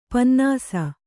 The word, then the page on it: ಪನ್ನಸು: pannasu